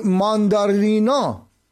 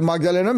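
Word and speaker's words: Magdalena: این مرد حتی به زبان سوئدی هم صحبت نمی‌کند و نام ماگدالن اندرسون رو نیز به درستی تلفظ نمی‌کند و او را ماندارینا می‌نامه، به جای ماگدالنا میگه ماندارینا، واژه‌ای که در زبان سوئدی به معنای نارنگی هستش.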